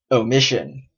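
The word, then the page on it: omission: Canadian English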